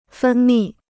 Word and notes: 分泌: fēn mì

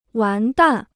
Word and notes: 完蛋: wán dàn

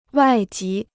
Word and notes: 外籍: wài jí

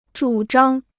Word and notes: 主张: zhǔ zhāng

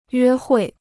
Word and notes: 约会: yuē huì

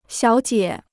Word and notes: 小姐: xiǎo jie

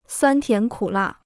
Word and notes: 酸甜苦辣: suān tián kǔ là

